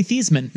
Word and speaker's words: Theismann: So it's actually Theismann.